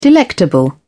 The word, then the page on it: delectable: British English